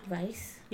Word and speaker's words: Weisz: Is that how you say her name? – Yeah. – Weisz? – Yeah. – Like with a V? – Yeah.